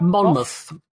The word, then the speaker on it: Monmouth: Monmouth. – Monmouth. – Yeah, it's Muth rather than Mouth. – Okay. – Even though it looks like Mouth.